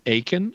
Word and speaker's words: Akin: It's really easy. It's just like it sounds. My last name is Akin, and so it's spelled A-K-I-N. There are no E's, T's, or S's. It's just four letters, Akin, A-K-I-N.